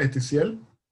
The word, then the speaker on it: Ethisiel: sí, ya pueden encontrarlo en Amazon y eh, pueden seguir eh, las redes sociales de la saga, que es, es arroba eticiel. Eticiel se escribe E-T-H-I-S-I-E-L.